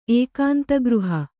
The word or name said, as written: ಏಕಾಂತಗೃಹ